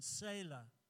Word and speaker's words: Selah: Well, it's the same pronunciation, Selah, spelled slightly differently, but it kind of fits with just taking time out and pausing and thinking.